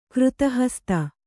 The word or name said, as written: ಕೃತಹಸ್ತ